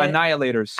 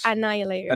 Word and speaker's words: annihilators: Family inhalers? – Right. – I knew they were going to make fun of that. – Yeah. What's the word, though? How do you say it? – Annihilators. – Annihilators.